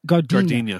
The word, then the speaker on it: Gardena: Am I saying that right? Gardena? – Gardenia. – I was in Gardenia the other day.